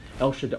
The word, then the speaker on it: El Shaddai: Literally in Hebrew, in terms of pronunciation, is El Shaddai, or Shaddai.